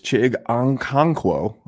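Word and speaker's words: Chig Okonkwo: which is Chig Onconquo. I'm 100% sure that I absolutely butchered that man's name. Huh? – I think you did okay. I don't think that was that bad.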